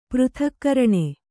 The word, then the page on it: ಪೃಥಕ್ಕರಣೆ: přthakkaraṇe